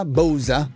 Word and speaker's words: Bausa: Oppure com'è che si pronuncia? Bausa? – Sì.